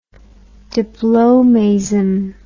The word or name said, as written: diplomaism